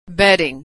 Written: betting

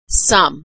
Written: some

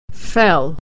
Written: fell

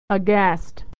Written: aghast

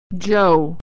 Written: Joe

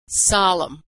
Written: solemn

